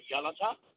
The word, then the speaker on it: Yalata: Just before I try and give an answer, you pronounce the town on the Nullarbor as Yalata. Yalata. It's actually pronounced Yalata.